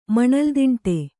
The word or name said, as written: ಮಣಲ್ದಿಂಟೆ